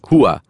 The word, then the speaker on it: hooah: Well, now you know, he was saying HUA, pronounced H-O-O-A-H.